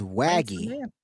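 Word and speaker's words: Waege: Name pronunciation, it's W-A-E-G-E, so it's Waggy.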